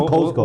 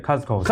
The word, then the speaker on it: Costco: Costco 发音要对哦，Costco 不是 Costco，我都念对 Costco。